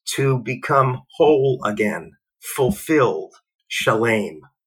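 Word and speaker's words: shalem (שלם): But then there's another pronunciation of those three self-same Hebrew letters, and the word is shalem, and it means fulfilled, whole, complete.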